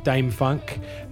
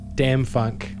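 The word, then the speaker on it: DâM-FunK: So this one, I'm really excited about Dame Funk. Not to be pronounced Damn Funk.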